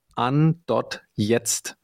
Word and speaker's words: an.jetzt: Um, the company name is actually An, so it's German for An, switching something on. So yes, An.jetzt is the right answer.